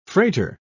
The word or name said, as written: freighter